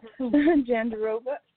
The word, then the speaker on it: Jandiroba: Jandiroba. – Tonight's fight. Yeah, Jandiroba versus Morandin.